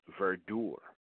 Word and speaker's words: verdure: V-E-R-D-U-R-E. I would pronounce that if I didn't have a pronunciation, I'd say verdure. But it's verdure.